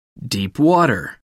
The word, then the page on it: deep water: American English